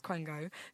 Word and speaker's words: quango: Firstly, it's pronounced Quango.